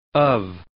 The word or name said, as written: of